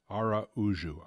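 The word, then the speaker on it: Araújo: Luis Araujo. – Araujo.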